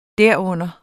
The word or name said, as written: derunder